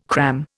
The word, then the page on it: cram: Indian